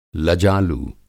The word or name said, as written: लजालू